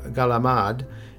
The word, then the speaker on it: calamari: Another seafood touchpoint that can be enjoyed in a variety of delicious ways on Christmas Eve, calamari, or galamad, as it's pronounced in some families, consists of the rings and tentacles of squid.